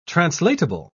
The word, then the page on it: translatable: British English